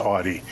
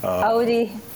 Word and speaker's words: Audi: And Audi is how I pronounce Audi. If okay. – that is wrong, please let me know.